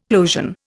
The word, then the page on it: plosion: Indian